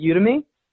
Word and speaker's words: Udemy: Yeah, I think I, I, I the a few different people pronounce it different ways. They actually pronounce it Udemy.